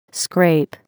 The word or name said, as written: scrape